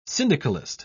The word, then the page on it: syndicalist: British English